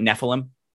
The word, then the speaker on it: Nephilim: At what point did you, like, was her being, am I pronouncing it right, Nephilim? Mm-hmm.